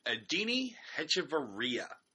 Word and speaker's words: Adeiny Hechavarria: Adeiny Hechavarria. It's a tricky is, one. Yeah, that's the that's the uh, proper pronunciation.